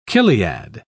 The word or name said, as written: chiliad